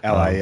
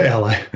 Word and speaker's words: Ally: Ally, yep. – Yeah, Ally.